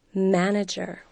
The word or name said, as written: manager